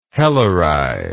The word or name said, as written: helleri